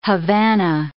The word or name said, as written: Havana